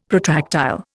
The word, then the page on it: protractile: Indian